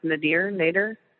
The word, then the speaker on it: Nader: Nader? Mm-hmm. Nader? – Yeah, it's Nader. He's like one Ralph that, Nader.